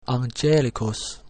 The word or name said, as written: angelicus